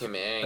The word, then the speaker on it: Ng: You know, that's Kim Ng. – That's kind of why I brought it into that, because she's... – That's with a silent N and G, correct? – Yes. – That's the proper pronunciation.